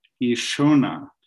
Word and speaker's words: Ishona: the Gaelic Ishona, with this, the SH is pronounced in a soft or almost hidden way in the Gaelic.